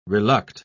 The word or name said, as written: reluct